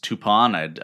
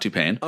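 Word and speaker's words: Toupane: The 15-man was finalized with Alex Toupon. I discovered is how you say it, not Toupain.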